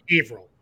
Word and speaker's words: Haverhill: It looks like it says Haverhill or Haverhill or Haverhill. It's Haverhill is how it's said in Massachusetts. So live with it. That's where the city is located. That's how we say it.